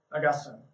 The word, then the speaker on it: Augustine: You guys have all heard of Augustine, or Augustine, depending on how you want to say it. It seems that Augustine's actually the correct way to say it.